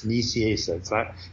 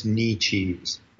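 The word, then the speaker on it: Nicieza: So then 10 minutes go by, and Fabian comes running up holding Cyclops by the arm, and he goes, "Dude, why did you tell him my name was Knee Cheese? That's not how you pronounce it. You know it's not how you pronounce it. Nisiesa, Nisiesa."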